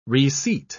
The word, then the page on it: reseat: British English